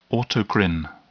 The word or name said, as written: autocrine